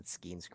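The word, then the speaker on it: Skenes: I'm just so happy you said Skeens correctly. – I've been saying it right for weeks, man.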